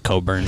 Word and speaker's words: Cockburn: And did they just beat Cockburn? – Yeah. – It's actually Coburn, but gotcha.